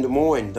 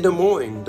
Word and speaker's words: Des Moines: What it means in Des Moines, the letter you don't pronounce when you tell people where you are from.